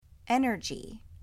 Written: Energy

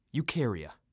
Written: Eukarya

